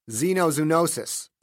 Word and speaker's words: Xenozoonosis: Xenozoonosis, Paul said, pronouncing the word correctly.